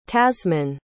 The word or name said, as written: tasmine